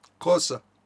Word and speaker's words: Xhosa: Xhosa.